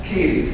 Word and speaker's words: Cady: My name is Wilson Katie. Am I supposed to give address, et cetera, or? – What's that? – Could you say your name? Wilson. – Katie, C-A-D-Y.